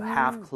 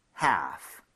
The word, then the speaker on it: half: Half closed. Okay? 자, 여기 L 발음은 하지 않아요. 그렇기 때문에 help라고 하시면 절대 안 됩니다.